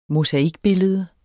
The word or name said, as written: mosaikbillede